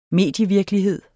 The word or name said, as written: medievirkelighed